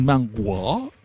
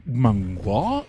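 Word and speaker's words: manhwa: La siguiente obra, porque no es un manga, es un manguo, manguo y lo dije bien, porque este es, eh, es de los Kansahamida, sí, es, es Okaseo, Hanaseo, este es eh, un, manga coreano.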